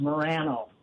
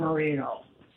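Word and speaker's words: Moreno: If you talk to anybody that has any Hispanic background and you look at the name, they'll say it's pronounced Morano, not Marino.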